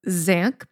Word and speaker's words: Dzanc: This one is from Zank Books. I don't know exactly how to pronounce that. Sure. D-Z-A-N-C. – That sounds right.